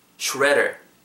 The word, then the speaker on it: shredder: 제일 핵심적인 단어 S H R E D D E R shredder 가는 그 기계 파쇄기 shredder, shredder, shredder. 영국식은 뭐 shredder 정도로 되는데 오늘은 shredder, shredder 정도로 발음할게요. – redder 발음하실 때 R 사운드 입술 말고 하는 데만 좀 더 초점 맞추시면 좋을 것 같아요.